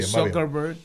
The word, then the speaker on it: Zuckerberg: Bueno, Mark Zuckerberg, así lo dije bien. – Sí, sí, sí. Sí, Zuckerberg.